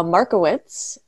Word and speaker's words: Markievicz: Uh, Markievicz, if I'm saying that correctly.